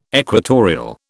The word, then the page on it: equatorial: British English